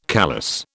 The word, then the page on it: callous: British English